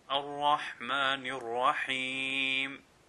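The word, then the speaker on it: الرحمن الرحيم: If the Ra has a Fatha or a Dhamma, that is, a Zabr or a Pesh, then it will be pronounced with a full mouth. Like, Ar-Rahmanir-Rahim, Ar-Rahmanir-Rahim. So the Ra here is being pronounced full mouth. If we were to pronounce it with an empty mouth, that would be incorrect.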